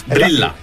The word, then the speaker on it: Brillà: Brilla. – Punto, è come brilla ma con l'accento sulla.